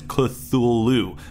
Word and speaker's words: Cthulhu: So that's why it's Cthulhu. But think of it more like crew or clue. If you could say that thule sound, it would be one syllable.